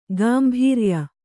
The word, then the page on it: ಗಾಂಭೀರ್ಯ: gāmbhīrya